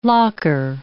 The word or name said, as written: locker